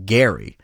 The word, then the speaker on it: Gerry: So it was former Massachusetts Governor Elbridge Gerry. – Spelled that way, though. – Spelled that way, okay. But with a hard G, because, of course, back then there was no television to tell you one way or another how the name was actually pronounced.